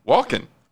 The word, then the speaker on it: Walken: Christopher Walken has joined the cast of Dune 2. Walken. Christopher Walken. Walken. – Walken. – Walken. – Walken. – Walken. – Walken. Nobody pronounces the L.